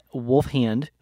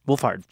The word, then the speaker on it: Wolfhard: And then Finn Wolfhand, who is Wolfhard. – Wolfhard.